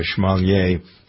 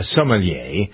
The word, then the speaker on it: sommelier: Jennifer is I've never pronounced this correctly, but mostly because I'm teasing you. I call you a schmalier, a sommelier,